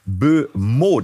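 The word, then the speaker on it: Mbeumo: Brian, ausgesprochen Bumeau, das ist ein Flügelspieler.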